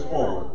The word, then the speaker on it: pho: It's pho.